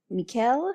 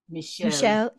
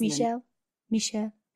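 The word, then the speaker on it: Michiel: I use The Invitation, and I'm never going to pronounce this, but Mikkel? Is that how you pronounce his first name? – Michelle. – It is Michelle.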